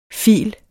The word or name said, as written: fil